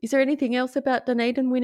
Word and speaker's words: Dunedin: I have Dunedin. Dunedin's pretty straightforward. You've got it.